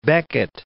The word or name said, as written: Beckett